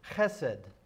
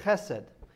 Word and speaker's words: chesed: It's the Hebrew word chesed. Okay? now, you might have to hold your hand up like this to, to say it correctly so that you won't uh, disturb your neighbor, but uh, it's the Hebrew word chesed.